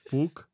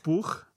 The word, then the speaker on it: Pugh: Jak se Puch. To čte? Puch? – Ne, Puch. Florence Puch a Timothée Chalamet. – Tak krásně, výslovnost na jedničku.